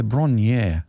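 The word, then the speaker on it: Bron-Yr-Aur: So Bron Yor is Welsh for Hill of Gold. Oh, it pronounces here Bronier. Oh, Bronier. There we go.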